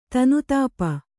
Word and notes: ತನುತಾಪ: kannada

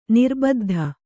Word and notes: ನಿರ್ಬದ್ಧ: kannada

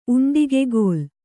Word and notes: ಉಂಡಿಗೆಗೋಲ್: uṇḍigegōl

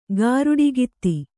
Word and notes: ಗಾರುಡಿಗಿತ್ತಿ: gāruḍigitti

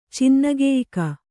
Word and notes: ಚಿನ್ನಗೆಯ್ಕ: kannada

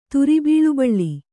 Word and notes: ತುರಿಬೀಳುಬಳ್ಳಿ: turibīḷubaḷḷi

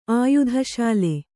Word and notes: ಆಯುಧಶಾಲೆ: kannada

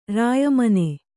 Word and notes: ರಾಯಮನೆ: Kannada

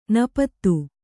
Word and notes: ನಪತ್ತು: napattu

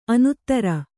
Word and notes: ಅನುತ್ತರ: anuttara

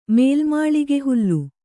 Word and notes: ಮೇಲ್ಮಾಳಿಗೆಹುಲ್ಲು: mēlmāḷige hullu

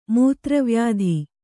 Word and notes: ಮೂತ್ರವ್ಯಾಧಿ: kannada

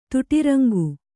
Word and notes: ತುಟಿರಂಗು: Kannada

